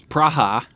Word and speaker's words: Praha: Right, and we pronounce it because the way it's pronounced in Czech is Praha.